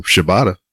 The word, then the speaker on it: Shibata: It's like, there's not too many that have similar to the Young Lions attire other than Shibata.